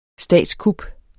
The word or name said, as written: statskup